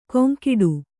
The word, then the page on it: ಕೊಂಕಿಡು: koŋkiḍu